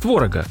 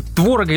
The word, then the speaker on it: творог: Ну, тут от себя спрошу, кстати, творога или творога все-таки, как утвердиться? – Ну, направление-то движение в сторону ударения творог. – А все-таки первый слог. – Но я по старинке говорю творог.